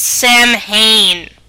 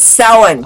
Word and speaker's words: Samhain: It's not pronounced Samhain. – It's Samhain.